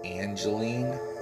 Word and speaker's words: Angelyne: Her name is spelled A-N-G-E-L-Y-E. Let me try that again. A-N-G-E-L-Y-N-E. Angeline.